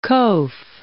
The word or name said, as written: qoph